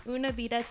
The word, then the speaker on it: Una Vida: He is the co-owner of Una Vida Tequila.